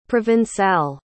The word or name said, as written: provençal